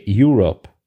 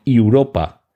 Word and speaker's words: Europa: os habréis dado cuenta que en inglés no se dice Europe, sino Europa, porque es una palabra latina, el Europa de la Luna, mientras que el Europa de continente sí, se escribe con una E al final en inglés y por tanto se pronuncia Europe.